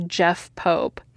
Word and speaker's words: Jeff Pope: This podcast was repeatedly interrupted by the guys from Ask a Ninja and written by Jeff Pope, not Joff Pope, as I pronounced it earlier.